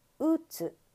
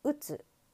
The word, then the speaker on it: うつ: このうつ、発音というのは最初にアクセントがつきます。うつ、うつ。これだけで言うときはうつ。でもうつっぽいのときはどうですか？ 2個目に、 2 番目に、アクセントがついています。うつっぽい。もしぽいというときはうつっぽいで最後につきます。